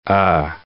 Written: er

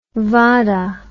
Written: vada

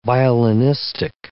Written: violinistic

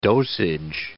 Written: dosage

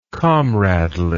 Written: comradely

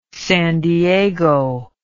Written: san diego

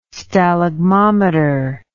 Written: stalagmometer

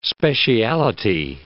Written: speciality

